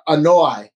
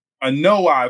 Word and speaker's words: Anoa'i: It's how do you pronounce it? – Anoa'i. – Anoa'i. Okay, I was saying Anoa'i.